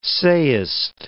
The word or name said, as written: sayest